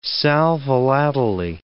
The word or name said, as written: sal volatile